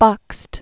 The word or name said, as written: Leon Nikolaevich Bakst